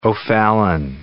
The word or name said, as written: O'Fallon